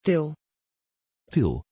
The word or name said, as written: Til